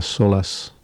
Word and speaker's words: Sòlas: el disco de Talita McKenzie que se titula Solas, como suena, con acento en la O, no sé todavía por qué. Talita McKenzie se escribe T-A-L-I-T-H-A,